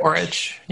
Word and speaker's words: Coric: And so over the weekend, two of the other stars in the tournament Grigor Dimitrov and I never say his name right, Borna Coric? Coric? – Coric, yeah.